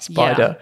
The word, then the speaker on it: SPDR: SPDR. Spider. Yeah. That's how it's said for short.